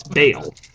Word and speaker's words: Bale: Yeah, it's not Bell, it's Bale. – Like a bale of hay. – I know, you're saying Bell instead of Bale.